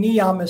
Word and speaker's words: niyama: So the second Y in niyama is soft because it's in the middle.